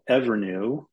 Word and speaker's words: Evrnu: I'll give you another example of a company that's called Evernew, which is spelled E-V-R-N-U.